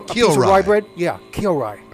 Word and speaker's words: Kyorai: How you spell that, Vic? – K-Y-O. – Yeah. – R-A-I is Kyorai. I'm sorry. Oh. I must humbly apologize to Kyorai. Oh. K-Y-O-R-A-I. Kyorai.